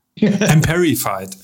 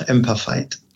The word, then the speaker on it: Amperfied: Heidelberg Amplified GmbH. Habe ich es richtig ausgesprochen? Nein. Ähm, schade. Emperified. – Emperified. – Ganz einfach Emperified.